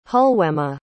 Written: hulwema

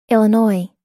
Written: illinois

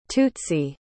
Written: tutsi

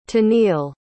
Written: tennille

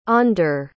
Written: ander

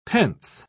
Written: pence

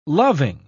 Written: loving

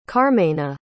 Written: carmena

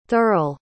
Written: thurl

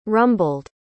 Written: rumbled

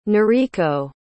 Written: nariko

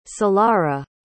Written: solara

